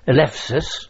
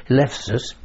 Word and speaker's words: Eleusis: And we were stationed at a place called Elefsis, spelled Eleusis, but it's pronounced Elefsis.